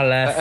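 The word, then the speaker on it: Leffe: É, eu também gostei da de uma que eu não sei como, como pronuncia, mas é L-E-F-F-E, Lef. – Ah, Lef. É, é Lef mesmo.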